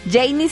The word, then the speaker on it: Jeiny: Por cierto, también así me pueden encontrar en redes sociales, arroba Janie Capistrán. Janie se escribe así, J-E-I-N-Y, ¿ok?